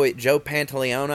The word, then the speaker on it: Pantoliano: Joe Pantoliano, I think is how you say his name.